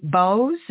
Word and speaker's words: Bowes: And I want to tell everyone, make sure I'm pronouncing your last name correctly. Bonnie Bowes, is that correct? – That is correct.